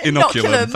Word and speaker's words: Inoculum: Inoculum.